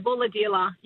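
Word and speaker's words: Bulahdelah: Before I say goodbye, on the text line, someone's asking me, how do you pronounce Bulladila? Have I got that right? Bulladila? – Yeah, Bulladila, yeah.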